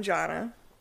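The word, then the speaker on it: Jonna: Well, I'm Jana. – How you spell that? – Let's J-O-N-N-A.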